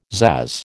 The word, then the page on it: pzazz: American English